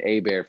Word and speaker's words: Hebert: Uh, Matt is bear for me, mm, and just Evan A bear for me. Uh, last name spelled like Hebert, so you'll find it.